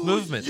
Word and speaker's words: MVMT: That's why I will never wear anything other than MVMT, pronounced movement sunglasses.